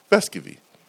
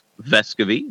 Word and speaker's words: Vescovi: pronounce it, then you can say Vescovi.